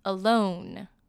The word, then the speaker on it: alone: 那他们的发音有些微的不一样哦，我来把两个都念一次，单独 alone，沿着 along Alone, along，这样大家有没有分清楚了呢？